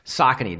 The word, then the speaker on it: Saucony: Saucony, that's how you pronounce it officially.